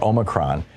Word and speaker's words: Omicron: It's titled, You Will Get Omicron, or Omicron. You hear both pronunciations these days. I think the long O is the formal one, the correct one, but the popular one is the short O.